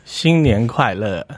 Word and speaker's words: 新年快乐: 新年快乐.